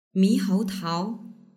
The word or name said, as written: mihoutao